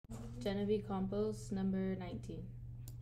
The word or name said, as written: Jennavee Campos